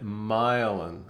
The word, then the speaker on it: myelin: sets of neurons fire to help you accomplish Cindy's showing me her phone that pronounces it myelin. Okay, so you pronounced it right. Myelin. Myelin.